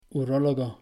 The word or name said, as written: urólogo